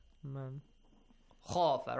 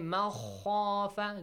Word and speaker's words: مَنْ خَافَ: Nun is clear, man. Yeah. Do not change it. وَأَمَّا مَنْ خَافَ مَنْ خَافَ Do not say مَخَافَ Do not merge it to خَ or any other rules.